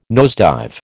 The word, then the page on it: nosedive: American English